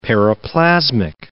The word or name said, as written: periplasmic